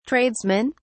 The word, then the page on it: tradesmen: British English